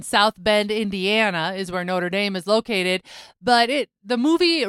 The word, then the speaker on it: Notre Dame: In the United States, we say Notre Dame.